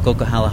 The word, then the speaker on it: Coquihalla: So we are in between Merritt and Hope, British Columbia right now on the Coquihalla Highway. I said it right.